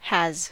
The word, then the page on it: has: American English